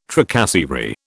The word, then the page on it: tracasserie: British English